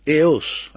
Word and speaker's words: Eos: Eos, I should pronounce it, of the Greeks.